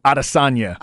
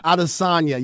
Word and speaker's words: Adesanya: Adesanya. – Adesanya, yeah.